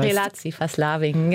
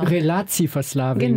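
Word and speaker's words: relatieverslaving: Ich weiß nicht, ob ich das jetzt richtig ausspreche, aber ein wichtiger Ausdruck ist dieses Relativverslaving. Wie heißt das? Das heißt Relativverslaving, genau. – Relativverslaving.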